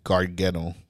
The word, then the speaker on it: Gargano: Otis versus Johnny Gargano. Did I say it right this time? – Yeah.